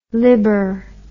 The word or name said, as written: libor